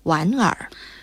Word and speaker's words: 莞尔: 哎，这不是莞尔的莞吗？ – 注意啊，这个字读第三声莞，基于后面的尔也是第三声，因此莞得变调，才读成了莞尔。